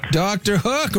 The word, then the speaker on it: Dr. Hook: Dr. Hook rhymes with Sam Cooke.